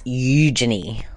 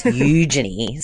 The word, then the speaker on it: Eugenie: Well, before we get to that, we've got to clarify your pronunciation of Eugenie. – Yes. – It's Eugenie. So we've been saying it wrong all this time, or I certainly have anyway, but it's Eugenie.